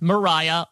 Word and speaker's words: Mariah: So her name is pronounced Mariah, like Carrie, Sharon.